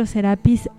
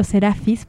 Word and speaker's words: Serapis: Su día es el día miércoles y su director es el maestro Serapis Bey o Serafis, es correcto ambas pronunciaciones, cuyo templo etéreo está en Long Island, que antes estaba en Luxor, también en Egipto.